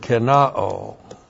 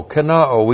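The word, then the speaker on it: kenao (κενόω): That is the Greek word kenao. K-E-N-O, long O, kenao.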